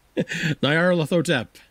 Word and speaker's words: Nyarlathotep: Nyarlathotep.